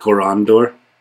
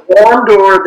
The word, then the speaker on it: Korondor: So Korondor, the stress goes in the middle because that syllable has an ND in it,